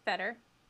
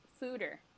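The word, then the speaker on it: foeder: It's not pronounced fetter. It's pronounced fooder. – What? – F-O-O-D-E-R is how you say it.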